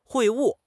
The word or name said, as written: 会晤